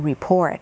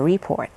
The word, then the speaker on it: report: ถ้าเป็น verb ละอ่านว่า report ถ้าเป็นคำนามละอ่านว่า report นะค